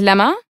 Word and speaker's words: Lemma: My real name is Lemma. But nobody can say it right because I end up sounding like an animal, the llama, because that's how people here say it.